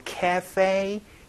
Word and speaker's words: cafe: 카페가 아니고 cafe. 이 발음 또 기억을 해 주시면 좋겠네요.